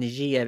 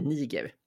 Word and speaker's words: Niger: Alltså om man ska använda franska eller engelska, liksom Niger eller Niger. – Jag, säger, Men... jag kommer säga Niger.